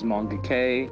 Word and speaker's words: Moonga: My name is Moonga K.